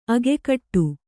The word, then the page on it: ಅಗ್ಗಡ: kannada